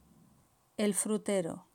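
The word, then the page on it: el frutero: Spain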